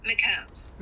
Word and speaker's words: McCombs: McCombs.